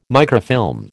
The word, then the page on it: microfilm: American English